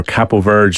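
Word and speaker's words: Cabo Verde: I mean, we've all kind of became Cape Verde or Capo Verde fans, depending on how you want to pronounce it, Anton, over the last few weeks, mainly because of the story of Pico Lopez.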